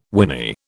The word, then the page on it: winy: British English